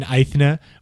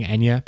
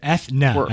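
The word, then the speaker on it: Eithné: Aethna, which is how they pronounced it on the stream, by the way, I know people have been saying Enya, but they did say Aethna.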